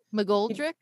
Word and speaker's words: Mcgoldrick: We are going to bring you Christina Mcgoldrick. Did I say that right? Mcgoldrick? – You did. – Okay. You did.